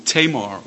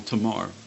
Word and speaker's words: Tamar: Or Tamar or Tamar, depends how you pronounce it.